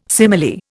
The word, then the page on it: simile: Indian